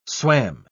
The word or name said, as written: swam